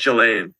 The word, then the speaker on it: Ghislaine: But Ghislaine Maxwell's on trial now, or Ghislaine, can never pronounce it. You know, I think it's Ghislaine. – Ghislaine, yeah. So Ghislaine Maxwell is on trial now.